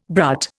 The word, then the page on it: brut: Indian